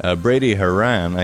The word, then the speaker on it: Haran: Brady Haran, I think that's how you say his name. Yeah, that is, oh no, John. I absolutely know that this is how you pronounce this man's name.